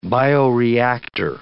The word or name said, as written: bioreactor